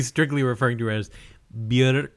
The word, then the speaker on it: Björk: And I've heard that, well, not only have I heard, but I also checked this on the internet because of the umlaut, right? It's like pronounced Björk.